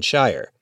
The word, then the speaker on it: Scheyer: John Shire. And just for the record, that's spelled S-C-H-E-Y-E-R. For NPR News, I'm Dave DeWitt in Durham, North Carolina.